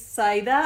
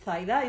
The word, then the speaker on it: Saida: Why, my name is Saida, as you pronounce in English. I would pronounce it Saida in Spanish because I'm from Spain and we have those weird phonemas.